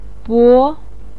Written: bo2

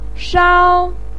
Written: shao1

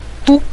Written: du5

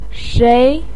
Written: shei2

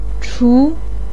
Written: chu2

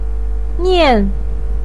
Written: nian4

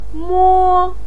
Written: mo1

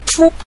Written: fu5

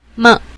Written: me

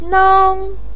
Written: nang1

